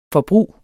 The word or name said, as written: forbrug